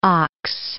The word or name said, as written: Ochs